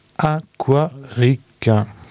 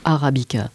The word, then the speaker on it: Aquarica: Donc tout ça pour vous présenter un album qui s'appelle, il faut faire attention à la prononciation, Aquarica. – Et non Arabica.